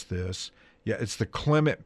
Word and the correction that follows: Clementa: Pinckney Law, Clementa C.